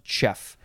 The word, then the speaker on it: chough: Next we have, now I'm not sure, do you pronounce this chef? Is it chef? It is spelled C-H-O-U-G-H. So can't get the words out of the mouth. I would have no idea how to pronounce this word if I just looked at it. Would it be cho, chof, co, cough? There are probably more options. Cow? Cow? I don't know. Anyway, it says it's pronounced chef.